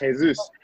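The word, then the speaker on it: Jesús: It's actually mm. pronounced Jesus.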